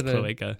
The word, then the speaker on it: cloaca: Oh, I thought it was cloaca, but is it cloaca? – It's cloaca.